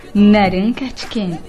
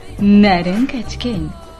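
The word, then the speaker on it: Narain Karthikeyan: Naran Kartiken. Que tem ele? Nada, é que eu aprendi a pronúncia correta do nome do indiano. É assim que se fala na Índia. Naran Kartiken.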